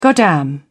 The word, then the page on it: goddamn: British English